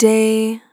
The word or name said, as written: Short Day